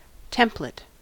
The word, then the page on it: template: American English